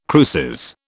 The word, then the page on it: cruces: British English